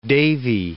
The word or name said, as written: davy